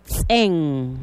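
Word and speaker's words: tz'en: Vamos paso a paso aprendiendo a escribir la palabra cama, que en yucatán se pronuncia tsen. Vamos despacio a escribir la palabra tsen. Empezamos con la letra T, la letra Z, el apóstrofo, que es como el acento, la letra E y la N.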